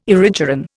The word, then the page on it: erigeron: Indian